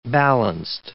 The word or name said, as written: balanced